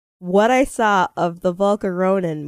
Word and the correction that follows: Volcanion: movie. Volcanion movie.